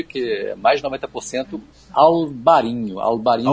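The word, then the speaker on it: Albariño: Em Portugal é Alvarinho com V e NH, e na Espanha é Albarinho com B de bola e o N com tio em cima, né, como se escreve em espanhol.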